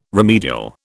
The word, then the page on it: remedial: British English